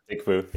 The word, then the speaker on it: PickFu: So you're going to be talking all about enterprise-grade consumer research, and you are the co-founder of PICKFU. Am I saying that right? PICKFU. – Yep, PICKFU.